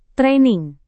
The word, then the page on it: training: Indonesian